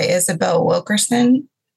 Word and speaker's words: Isabel Wilkerson: I think definitely I love anything by Isabel Wilkerson.